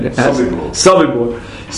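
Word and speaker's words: Sobibor: So in one of the movies, I think it's called, I forget what it's called, Retreat from Sobibu or something. I'm not pronouncing it right. – Sobibu.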